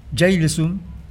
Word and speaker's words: Hejlesen: Hablamos del guitarrista danés, a ver cómo me sale esto, Kasper Jailesum, así más o menos se pronuncia esto.